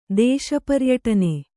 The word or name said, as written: ದೇಶಪರ್ಯಟನೆ